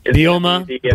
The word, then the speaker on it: Bielema: I think that's how you say his name. – Bielema, Brett Bielema.